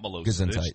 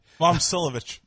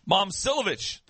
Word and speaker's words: Momcilovic: It's not Milosevic. That's Matt Milosevic from WLKY. – Momcilovic. – Momcilovic. Thank you.